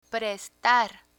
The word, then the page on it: prestar: Spanish